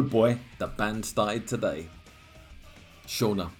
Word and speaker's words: Sean: This is where Sean, pronounced Shauna, has to fill gaps.